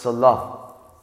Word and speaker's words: Selah: All right, today's message, Selah, or in Hebrew it's pronounced Salah.